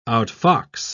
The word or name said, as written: outfox